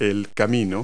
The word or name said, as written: path